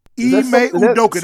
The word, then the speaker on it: Ime Udoka: Did you know that the head coach is it Ime Udoka? – Ime Udoka. That sounds very close.